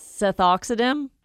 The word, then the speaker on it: Sethoxydim: Cethoxidim, I hope I'm saying that right, but that's going to be the active ingredient in the herbicide that you're wanting to use. So it's Seth, S-E-T-H, O-X-Y-D-I-M.